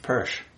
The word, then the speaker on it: Persch: But yes, there are people in the world who just forget, but it's pronounced Persch.